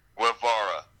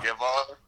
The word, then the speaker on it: Guevara: I butchered that. Guevara. – Yeah. – Guevara.